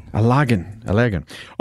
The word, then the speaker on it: Allergan: Allergan.